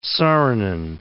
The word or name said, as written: Saarinen